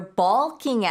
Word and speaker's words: balk: So if you're bulking at something, it's spelled B-A-L-K.